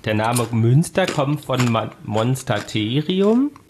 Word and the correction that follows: Monasterium: Also, Monstaterium, nee, groß. – Monasterium. – Ah, falsch ausgesprochen.